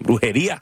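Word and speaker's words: Brujería: C'est important de bien prononcer. Brujeria de Helbujo, Bomba Estéreo et Li Sommet.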